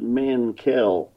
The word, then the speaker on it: Mankel: Well, I would just pronounce it Mankel. – Mankel. – Just an equal accent on both the syllables.